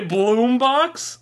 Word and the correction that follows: Boombox: I guess I did. I meant Boombox.